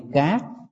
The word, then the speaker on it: cát: Quý vị nhớ, khi mà nói tới chữ Hán phải đọc là chữ cát, chứ đừng đọc chữ cắt.